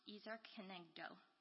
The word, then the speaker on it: Ezer Kenegdo: The Hebrew word for help suitable is pronounced Ezer Kenegdo.